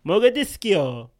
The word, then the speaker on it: Mogadiscio: I think Mogadishu is the English version with Mogadiscio. – That's what I said. – Being the Italian. Oh, Mogadiscio is like the Italian one.